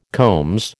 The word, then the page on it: combs: American English